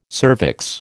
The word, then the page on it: cervix: American English